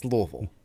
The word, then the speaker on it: Louisville: That's how you say it. It's Louisville.